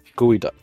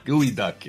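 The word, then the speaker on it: geoduck: The largest burrowing clam in the world is what phallic-looking clam whose name pronunciation could be misunderstood to be a soft and sticky waterfowl? – This is the very, very pornographic-looking gooey duck. – The gooey duck is correct.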